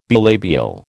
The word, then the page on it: bilabial: American English